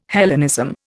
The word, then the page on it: Hellenism: Indian